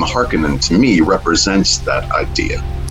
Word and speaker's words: Harkonnen: And based on they were geologically when they were on Earth, that's how their name would be pronounced. So, yeah, as far as Frank Herbert is concerned, and as far as I'm concerned, it's always going to be Harkonnen. – Harkonnen it is.